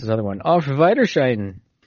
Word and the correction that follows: Auf Wiedersehen: I think that's Auf Wiedersehen.